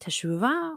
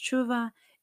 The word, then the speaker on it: teshuvah: And teshuvah, also sometimes pronounced tshuva, is the act of repentance.